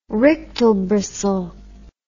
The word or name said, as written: rictal bristle